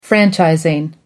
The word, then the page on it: franchising: American English